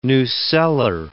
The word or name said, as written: nucellar